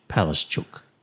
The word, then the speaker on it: Palaszczuk: I've been told that Anastasia Palaszczuk, our premier, the pronunciation of her name is in Polish Palaszczuk.